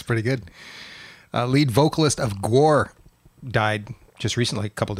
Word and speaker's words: Gwar: I say Gwar, but it is Gwar, isn't it? – Yeah. – Yeah, because it's war. – War. – Yeah. – G-war. – It's a G-war.